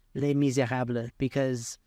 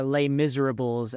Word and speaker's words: Les Misérables: And again, watch Les Misérables, or Les Miserables, as Americans say it.